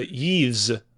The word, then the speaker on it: Yves: Is it Yves? How do you say it? – It's a Yves Yves? – Guillemot.